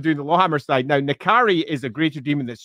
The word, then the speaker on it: N'Kari: Um, although if you are a hard pronunciation expert, you'll know that the uh, dash, the dash, pardon me, the apostrophe makes it a glottal stop. So it should actually be Nkari.